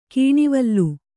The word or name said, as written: ಕೆನೆವಾಲ್